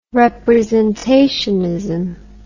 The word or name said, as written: representationism